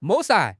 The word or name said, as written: 抹杀